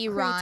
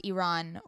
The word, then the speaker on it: Iran: Like it hit me that when someone experiences a tragedy, let's say in Iran or Iraq, it's that Iran. Creates a ripple it's effect. Pronounced Iran or Iraq.